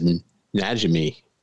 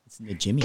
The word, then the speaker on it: Najimi: I always thought her name was Najimi. I was mispronouncing it. – Najimi.